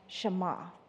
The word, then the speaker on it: Shema: It's spelled S-H-M-A, but pronounced Shema.